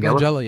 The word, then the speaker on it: Langella: Frank Langella, yeah.